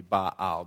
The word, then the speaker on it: Baal: Now, the people had started to worship Baal, which is one of the pagan gods, and if I wanted to be really strident about the Hebrew pronunciation, that would probably be Baal, because anytime the verbs vowels are next to each other, it kind of makes that stoppy, starty sound.